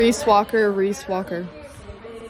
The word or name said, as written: Walker